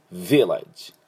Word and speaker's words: Village: で、日本語だと Village ってレの方が強くなってしまいますけど、 V にアクセントがあります。 Village、 Village やりません、ね、 Village 頭にアクセントありますので、ここ要注意ですね。